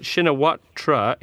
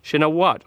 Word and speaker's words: Shinawatra: just a quick note to Jimbo that Shinawatra is pronounced with a silent ra, Shinawat, or Shinawat.